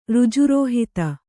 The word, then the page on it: ಋಜುರೋಹಿತ: řjurōhita